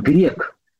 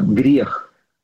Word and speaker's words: грех: Ну, неправильное произношение слова грех, да, по-русски правильно, грех.